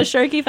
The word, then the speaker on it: Schoerke: and that is the Shirky Foundation. Is that the way to pronounce it? – That's actually the perfect pronunciation, the Shirky Foundation. – Shirky, well, let's spell it for people. S-C-H-O-E-R-K-E.